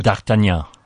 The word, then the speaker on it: D'Artagnan: One of the musketeers, D'Artagnan, how do you pronounce it? – D'Artagnan. – Okay, that sounds a lot better than what I said.